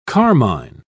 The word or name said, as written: carmine